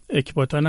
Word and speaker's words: اکباتان: که شد همدان به گویش فارسی امروز، و اکباتان هم تلفظ یونانی همون نامه.